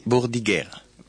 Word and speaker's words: Bordighera: Bordighera.